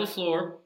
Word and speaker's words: LaFleur: They have recently hired Matt LaFleur, if I can pronounce that correctly.